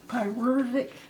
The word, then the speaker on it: pyruvic: So there is a particular acid called pyr. I'm, I'm gonna butcher this up. – Pyruvic. – Pyruvic. Pyruvic.